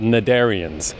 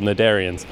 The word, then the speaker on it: Cnidarians: They're called Cnidarians. or Knudarians, if you want to pronounce the C. There's a weird way you can pronounce it with the C, but I was thinking of taking away the C and just calling them Knudarians with an N, but then it looks like Narnia, so I'm going to leave the C.